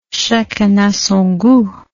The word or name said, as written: chacun a son gout